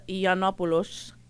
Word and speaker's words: Yiannopoulos: So basically, there was a horrible man who you're going to pronounce it, because I can't, because he's Greek and his last name is Yiannopoulos. – Yes. – That's the very Greek way of saying it.